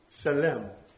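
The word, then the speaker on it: selim (צֶלֶם): The Hebrew word here for image is pronounced selim. Selim.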